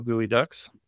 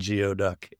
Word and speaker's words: geoduck: I went to the Evergreen State College in Olympia, go Gooey Ducks. And I for those of you non-Washington people, yes, that is the way you pronounce it. – It's not Geoduck, yeah, even though that's how it's spelled.